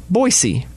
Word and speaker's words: Boise: Most people say with a Z, but it's actually Boise with an S.